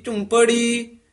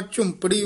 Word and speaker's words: झुंपड़ी: नानक हब अडंबर कूड़िया सुन जीवा सच्ची सोए, पौड़ी बस्ता टूटी झुंपड़ी चीर सब छिन्ना। – इसी तरह झुंपड़ी उच्चारण करना, झोंपड़ी नहीं कह देना है, औंकड़ लग्या।